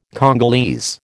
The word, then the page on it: Congolese: American English